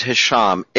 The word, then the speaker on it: Hesham: Spelling it's the first name is Hisham, H-E-S-H-A-M, and the last name is Tillawi, T-I-L-L-A-W-I.